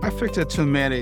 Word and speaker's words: affect attunement: In the next episode, you're going to share something called affect attunement. How do you pronounce that? – Affect attunement, yes.